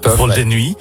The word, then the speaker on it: Vol de Nuit: And also, 20 years ago, you made Vol de Nuit together. – Yeah, I hope I pronounced it in the right way. – Perfect. – Vol de Nuit.